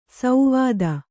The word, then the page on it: ಸೌವಾದ: sauvāda